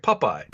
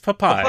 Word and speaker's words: Popeye: Well, the way you're saying it sounded like pup, like a pup, like Popeye. – Popeye. – Popeye. – It's Popeye. Popeye. Popeye and Blotol. I'm not mispronouncing it. It's Popeye.